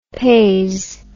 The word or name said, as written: peise